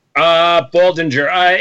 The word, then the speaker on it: Baldinger: But it's that D in there, though. Like, do you actually pronounce that D? – Baldinger. It depends. Seriously, it depends on which uncle you ask.